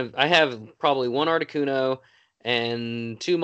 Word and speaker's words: Articuno: Articuno. – The blue. Yeah. Articuno.